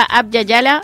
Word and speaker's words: Abya Yala: de la cooperativa Abya Yala de eh, Fátima.